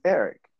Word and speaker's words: Erik: And my name is Eric, spelled with a K. And I'm like, I'm just gonna go with Eric.